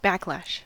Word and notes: backlash: American English

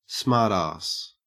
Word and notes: smartass: Australian English

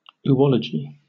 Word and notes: oology: British English

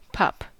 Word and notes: pup: American English

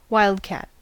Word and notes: wildcat: American English

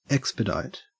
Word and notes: expedite: Australian English

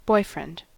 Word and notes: boyfriend: American English

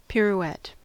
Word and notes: pirouette: American English

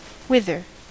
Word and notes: whither: American English

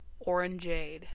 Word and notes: orangeade: American English

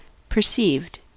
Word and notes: perceived: American English

